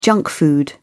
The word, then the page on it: junk food: British English